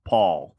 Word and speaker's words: Toupal: Well, let's we'll separate it into two syllables. – Okay. – Ho, Toupal.